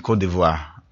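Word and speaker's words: Côte d'Ivoire: It's Friday, and Cote d'Ivoire.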